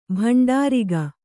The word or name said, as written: ಭಂಡಾರಿಗ